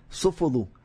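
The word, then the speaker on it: Sofuoğlu: A pronúncia é? Sofoglu. – Ah, não pronuncia o G.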